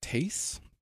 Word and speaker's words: Thais: So this hetaira named, I think it's pronounced Tais, T-H-A-I-S.